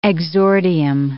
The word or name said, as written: exordium